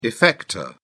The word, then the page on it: effector: British English